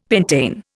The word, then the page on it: pentane: Indian